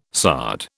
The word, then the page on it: sard: British English